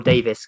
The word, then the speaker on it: Davies: I saw Ben's ears prick up when Nathan compared Celik to Ben Davies, and I thought, or Davis. God, I need to learn how to say his name. Ben Davis.